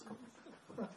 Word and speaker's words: kaput: If it's something like doesn't work, then it's kaput. – But that's not Hindi. That's not shrewd Hindi. That's German. Kaput! You got to pronounce it properly, like a German, isn't it?